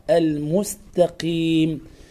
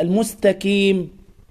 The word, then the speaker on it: المستقيم: وبعضهم يقرأ القاف هكذا المستكيم، أن يقرأها كافا، هذا خطأ. القاف في أقل درجة من درجات التفخيم، لكن لا يبالغ حتى لا ينطق القاف كافا، فلا يقول المستكيم، المستقيم. صراط